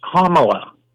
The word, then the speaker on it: Kamala: Firstly, I have to correct your pronunciation of something. You were saying it's Kamala. Kamala.